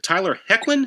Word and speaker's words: Hoechlin: Mike also wrote and directed the 2018 film The Domestics, starring Kate Bosworth and Tyler Hoechlin. Is that right? – That's right. Yep. – All right. – You said it right.